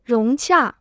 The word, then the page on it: 融洽: róng qià